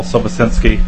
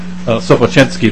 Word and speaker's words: Sobochinski: My name is Paul Sobochinski. I work with the Land Stewardship Project. I'm also a farmer. Thank you for the pronunciation. That's how the way my father would say it, the Polish pronunciation.